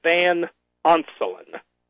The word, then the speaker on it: Van Onselen: It's Charles Van Onselen. Charles, just like it sounds, capital, last name is Van Onselen. It's spelled capital V-A-N, then capital O-N-S-E-L-E-N.